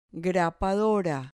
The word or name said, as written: grapadora